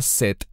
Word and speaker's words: Set: Normalmente Shet se pronuncia Set en español.